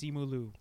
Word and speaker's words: Simu Liu: Yes, Simu Liu.